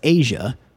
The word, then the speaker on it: Aja: This is from Steely Dan off of their album Asia, A-J-A, pronounced Asia.